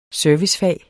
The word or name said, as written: servicefag